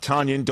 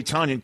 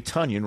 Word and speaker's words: Tonyan: Net Robert, not Tonyan. Don't call me Tonyan. Call me Tonyan. Rhymes with Funyan.